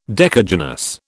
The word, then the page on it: decagynous: British English